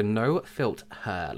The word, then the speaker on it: No Filt Her: And there's a powder in there, so called the No Filt Her, like filter, but Filt Her. Universal Complexion Brightener. I said that right the first time.